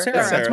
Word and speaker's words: Cera: How do you say that fucking name? Kara? Michael Kara? Sarah? Whatever. – Sarah. D-E-R-A. Yeah, Michael Sarah. – Sarah. That's Juno. Juno Boy. Sarah. Juno Boy. Yeah, Michael Cera.